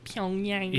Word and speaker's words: Pyongyang: I think you said that correctly. Pyongyang.